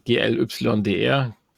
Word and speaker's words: GLYDR: Also Glider wahrscheinlich ausgesprochen, aber ohne E oder A am Ende. Also GLYDR.